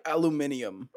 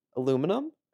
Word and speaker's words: aluminium: Why did you say that like a British person? – Aluminium? – Yeah. – Is that not how it's spelled? – Aluminum? – Aluminium? – That's how the Brits say it. – The Brits? – Yeah. – Oh. I don't. Why? Wait, what do you mean? – You're not saying it like an American.